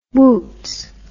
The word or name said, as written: wootz